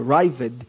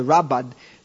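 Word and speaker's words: Raavad: the Rabbad, you know who I mean. The proper pronunciation, as they taught me in Israel, is the Rabbad, but it's very, very difficult for me to leave the Ravid if whenever the Rav would,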